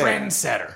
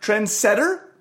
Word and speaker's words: trendsetter: How am I supposed to say trendsetter? – It? Trendsetter. – No, take the emphasis off setter, you weirdo.